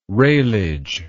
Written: railage

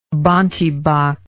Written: bontebok

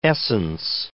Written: essence

